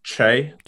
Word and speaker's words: Chay: Bro, honestly, it's actually pronounced like Che. Like C. H-A-Y, but I get Shay. I honestly, I'm so immune to like hearing it pronounced differently now. I'm just sort of like, whatever you want to call it is just easiest, but yeah, Che Rood. – Technically Che. That's it.